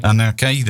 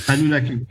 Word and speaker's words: Anunnaki: He felt that had been brought here by, is it the, how do you pronounce it? Anunnaki, that's it. – Anunnaki.